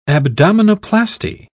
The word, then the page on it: abdominoplasty: British English